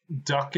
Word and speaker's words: douc: Duck in Danger, yeah. – Yeah, I think the pronunciation that I looked up to watch the video for, I think said duck, but D-O-U-C, it's the monkey.